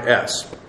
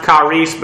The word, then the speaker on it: charis: Technically, I know the term is pronounced charis, but most English speaking people, when they see this, they will pronounce the I in a short form. They won't pronounce it like a long E as it is in the Greek.